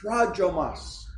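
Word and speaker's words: prajomos (πρόδρομος): This word forerunner is pronounced prajomos in the Greek.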